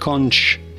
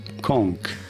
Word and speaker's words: conch: No, it is not conch shell, but rather conch shell. Just consider there is a K sound.